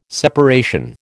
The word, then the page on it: separation: American English